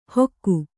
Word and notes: ಹೊಕ್ಕು: Kannada

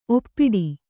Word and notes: ಒಪ್ಪಿಡು: kannada

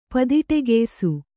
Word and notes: ಪದಿಟೆಗೆಯ್ಸು: padiṭegeysu; Kannada

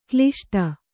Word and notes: ಕ್ಲಿಷ್ಟ: kliṣṭa; Kannada

